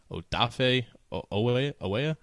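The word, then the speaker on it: Odafe Oweh: They have a defensive rookie of the year candidate in Odafe Owea. I believe I'm pronouncing that correctly. I practiced that really hard before the show started. You got it.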